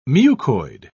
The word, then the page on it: mucoid: British English